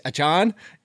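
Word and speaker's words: Achane: And then all of a sudden you're telling me it's A-Chan.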